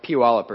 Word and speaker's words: Puyallup: But it's not as bad as, you know, Puyallup or something like that.